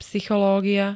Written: psychológia

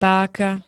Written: páka

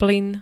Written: plyn

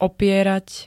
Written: opierať

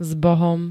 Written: zbohom

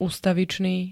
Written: ustavičný

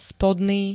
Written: spodný

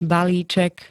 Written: balíček

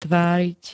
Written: tváriť

